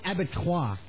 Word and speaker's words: abattoir: No, at the end of the day, the battlefield of Hastings looked like an avatar. – It's pronounced abattoir.